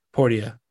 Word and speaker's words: Portia: And I think they pronounce it Portia. – Portia. – I'm like so ready to move on. – Like Portia de Rossi.